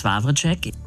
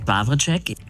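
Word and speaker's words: Wawrczeck: Ich will wirklich nicht klug scheißen, aber der Sprecher von Peter Shaw heißt Jens Wawracek, nicht Wawczek. Jens Wawracek. – Ja, vielen Dank, Yvonne, für deinen Hinweis. Du hast natürlich völlig recht. Er wird natürlich nicht Jens Wawracek ausgesprochen. Fragen wir ihn doch selbst. – Also, mein Name ist Jens Wawracek. Wawracek. – Wawracek, Jens, ist das richtig? Okay, jetzt wissen wir es.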